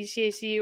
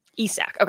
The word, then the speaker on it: ECAC: We either call it the ECAC or the ESAC. – ESAC.